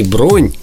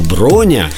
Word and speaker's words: броня: Оказывается, правильно произносить не, э, когда бронируешь билеты, не бронь, как мы это все делаем, или большинство людей, а броня. Правильно, я не ошибся? – Ну, Дима, вы почти не ошиблись. Броня – это правильный вариант, это общеупотребительный вариант, но вариант бронь, он тоже уже правильный, но считается немножко более разговорным.